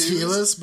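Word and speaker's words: Heelis: were conducted by William Helis, which is spelt like Healy's. I wanted to like make sure it was Helis, but it's spelt like Healy's.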